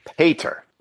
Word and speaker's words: Pieter: Okay, the official pronunciation, because it is a Dutch right. spelling of the name Peter, it's Peter. So it's almost an A, but it's still an E. – Peter.